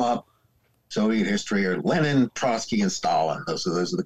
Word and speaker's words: Trotsky: how do you pronounce it? Trotsky or what? How do you? – Trotsky. – Trotsky. Sorry. Sorry about that.